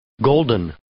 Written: golden

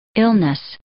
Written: illness

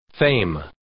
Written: fame

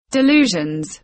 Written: delusions

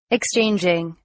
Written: exchanging

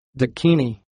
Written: dakini

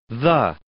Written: the

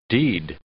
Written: deed

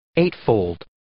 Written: eightfold

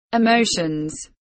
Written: emotions

